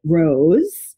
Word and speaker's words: Roz: And she also just launched the most incredible hair product company called Rose, R-O-Z, but pronounce it like Rose, like a flower, but cooler.